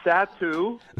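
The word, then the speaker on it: Satou Sabally: Then they're also a big from Berlin, 6'4", another pronunciation alert, Satou Sabali. How close am I? – Dang, 500 again. this time it's Satou. All right, Sabali. – All right, so not bad. – Half right.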